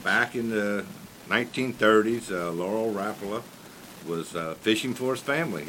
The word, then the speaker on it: Rapala: So, one of the things about it is everybody's heard of Rapala, but that's not how you pronounce it, is it? – Rapala. – Rapala.